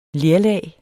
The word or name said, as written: LERLAG